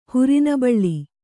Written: ಹುರಿನಬಳ್ಳಿ

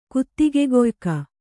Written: ಕುತ್ತಿಗೆಗೊಯ್ಕ

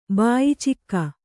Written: ಬಾಯಿಚಿಕ್ಕ

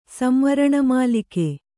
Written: ಸಂವರಣಮಾಲಿಕೆ

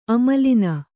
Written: ಆಮೇಲಿನ

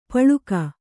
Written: ಪಳಿಗೆ

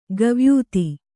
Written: ಕವಾಯಿತು

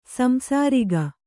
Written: ಸಂಸಾರಿಕ

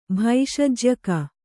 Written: ಭೈಷಜ್ಯಕ

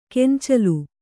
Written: ಗೊಂಚಲ್

